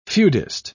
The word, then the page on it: feudist: British English